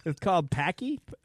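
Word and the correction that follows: Pocky: I think I'm saying that right. – Pockies. – Pockies? Okay.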